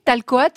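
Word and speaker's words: Tal-Coat: Talcoat, je, présente, je prononce bien Parfaitement.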